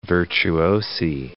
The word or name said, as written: virtuosi